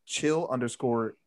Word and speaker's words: Chillus: Everything that Lance just said, apart from the Twitter handle, I go by Chill_US, CHILL_US. That's how you pronounce my last name, Chillus.